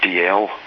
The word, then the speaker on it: Dalziel: The Z actually features in a couple of other Scottish surnames, like DL, which is spelled D-A-L-Z-I-E-L, which is again another Gaelic word, Dal Yal, which means a white dale. And it also features in the name Yule, which nowadays is mainly spelt Y-U-L-E or Y-U-I-L-L, but there are still some variants who spell it Z-U-I-L or Z-U-L-E.